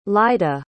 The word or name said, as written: lyda